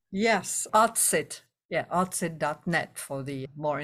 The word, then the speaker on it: Autsit: Autsit? – Yes, autsit.